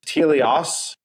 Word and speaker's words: teleios: and teleios. Am I saying that right? T-E-L-E-I-O-S. Is that teleios? – Teleios. – Say it one more time.